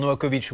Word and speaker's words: Novakovic: Simplement, dites bien Vitch sur Novakovic. Vous prononcez ça comme une quiche.